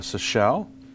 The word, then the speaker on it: Seychelles: Bahrain, Canada, France, Italy, Netherlands, Norway, Seychelles, and Spain.